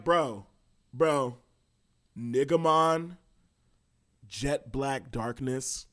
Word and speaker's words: Negamon: And the Digimon's name is spelled N-E-G-A-M-O-N and pronounced Negamon.